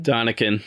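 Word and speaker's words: Däniken: So your friend and listener of the show, Eric Von, how do you say his name? Daniken? – Donikin.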